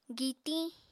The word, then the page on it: gitiñ: Apatani